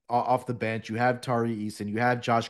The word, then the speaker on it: Tari Eason: Is it Tari Eason? Am I saying that correctly? – Yeah.